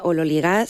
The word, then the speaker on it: ololigás: Teóricamente se pronuncia ololigás según la, bueno, pues la transcripción fonética del griego al español,